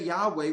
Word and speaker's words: Yahweh: You're not supposed to say the name Yahweh by closing your mouth or utilization of your tongue. You're actually supposed to say Yahweh with the first syllable on the inhale and the second syllable on the exhale without closing your mouth and without utilizing your tongue.